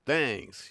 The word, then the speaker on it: Thangs: and that's exactly the way it's spelled, T-H-A-N-G-S, Fangs, you know.